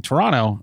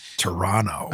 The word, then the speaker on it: Toronto: Toronto. – I know. We don't pronounce the second T. It's a thing. If you do pronounce the second T, you're not actually from there.